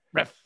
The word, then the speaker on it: Refsnyder: Ruff? Is it Ref? – Ref.